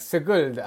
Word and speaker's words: Sigulda: It's funny, every time I say Sigulda, and you're like, say, like, Sigulda, like, how do you pronounce it, the town? – Sigulda.